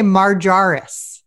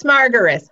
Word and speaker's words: Margaris: I always want to say Marjaris. – I get all kinds of different stuff, but it's Margaris. Hard G.